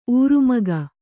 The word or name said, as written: ಊರ್ಮಿಕೆ